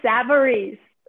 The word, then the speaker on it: Savarese: I'm here with special author Mary Savarese, and I probably butchered that last name for you. – No worries. Mary Kay Savarese.